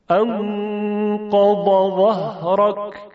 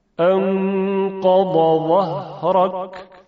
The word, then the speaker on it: انقض ظهرك: در اینجا حرف ضاد انقضا که بعدش حرف وای دستدار هست، انشاءالله حرف وا رو بعداً توضیح خواهیم داد، اما به نحوه تلفظ دقت کنید. – انقض ظهرك انقض ظهرك.